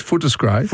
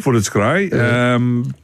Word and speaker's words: Footscray: Pronounce it properly. It's Footscray. – Footscray.